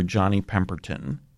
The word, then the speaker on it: Johnny Pemberton: Up in Sanford, Florida, opening for Johnny Pemberton.